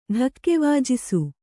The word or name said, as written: ಢಕ್ಕೆವಾಜಿಸು